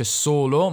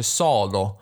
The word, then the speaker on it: solo: Ah, in realtà volevo anche farvi sentire come eh, Caparezza pronuncia solo, non dice solo, ma dice solo. Anche qui la, ha una bella O aperta, risentiamo questa O di solo.